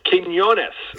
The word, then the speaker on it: Quiñones: Quiñones, I think is how you say his name. – Quiñones.